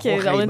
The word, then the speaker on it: Roraima: qui s'appelle le plateau de Romera, Roraima. Voilà, j'ai toujours de la difficulté à le prononcer. – C'est correct. Roraima.